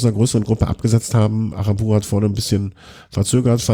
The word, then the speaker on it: Aranburu: Kollegen Alex Arana, wie spricht man das? Aranburu. Ja.